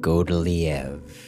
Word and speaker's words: Godeliève: When I was a young man, I fell in love a few times, but the last time was with Godeliev. – That's God plus love equals Godeliev in arcane French.